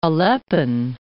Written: Aleppine

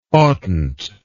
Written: oughtnt

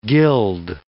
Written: gilled

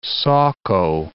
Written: Saco

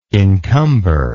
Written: incumber

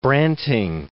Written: Branting

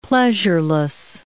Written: pleasureless

